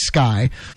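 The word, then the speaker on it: SCCY: In this case, the Sky, pronounced Sky, and their new handguns.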